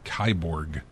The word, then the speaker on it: Kiborg: Kyborg.